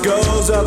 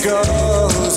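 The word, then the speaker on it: Gose: It's pronounced Goza, but some say Goze.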